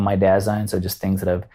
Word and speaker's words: Dasein: It is Dasein Health. So that is the correct pronunciation. – And don't let anybody tell you otherwise.